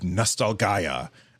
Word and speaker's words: Nostalgaia: It's just a demo, but you guys definitely want to check out The Last Hero of Nostalgaia. Which is how it is pronounced.